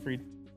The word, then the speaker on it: Fried: Fried.